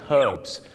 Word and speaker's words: herbs: สมุนไพรชาวอังกฤษก็จะออกเสียง herbs ตัว h หอนหูชัดเจนมากและชาวอเมริกาเยอะมากครับออกเสียงว่า herbs herbs ไม่มีตัวหอนหูก